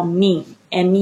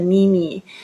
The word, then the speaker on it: meme: 就类似像这样子的，这个就叫 meme，m e m e，不要念成咪咪，它就是有梗的图片。